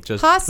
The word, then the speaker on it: Jasso: Jasso, like an H.